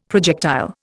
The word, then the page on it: projectile: Indian